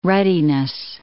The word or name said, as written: ruddiness